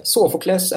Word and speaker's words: Sofokles: Sofokles är väl det uttalet jag fått lära mig sedan barnsben, men man kan nog uttala det på många olika sätt.